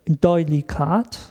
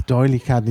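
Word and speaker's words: D'Oyly Carte: Ach, Doylicard, nicht. Also ich habe das Französisch ausgesprochen, weil der erste Teil des Namens Französisch. – Richtig. – Ist, aber der ist wahrscheinlich völlig. – Der ist in London geboren und in London gestorben.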